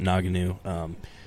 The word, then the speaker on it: Ngannou: And as much as prior to all this, how much I wanted to see him come back and potentially fight Ngannou and Nagana. – I believe you pronounce it Ngannou.